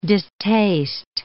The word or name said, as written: distaste